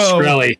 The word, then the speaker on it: Shkreli: Shkreli, yeah.